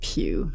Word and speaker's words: Pugh: And then the preface here by Mary Moody Pugh, P-P-U-G-H. – I think you pronounced that pew.